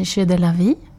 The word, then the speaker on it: Jeux de la Vie: Ditt danskompani, Kompani Jeux de la Vie, jag vet inte om jag uttalar det rätt? – Det låter jättebra.